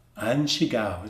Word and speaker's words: An Shigao: And the earliest known translator, the earliest known one, the one that we have a record of, his name was An Shigao. An Shigao, it's spelled like A-N-S-H-I-G-A-O.